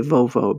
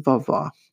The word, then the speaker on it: vovó: And a lot of people, you know, read my book and they say vovó because that is kind of how it's spelled, V-O-V-O. But it's pronounced vovó.